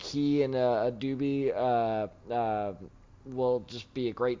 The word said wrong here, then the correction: Chidobe Awuzie: I know I mispronounced that. Leave me alone. – Chidobe Awuzie, I believe, is his name.